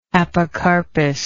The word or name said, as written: apocarpy